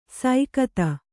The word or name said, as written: ಸೈಕತ